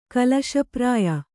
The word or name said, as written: ಕಳಸಪ್ರಾಯ